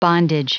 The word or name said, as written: bondage